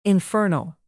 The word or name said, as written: infernal